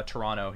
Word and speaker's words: Toronto: So he says, Devin from Toronto here again. Yes, you say it right. The second T is silent.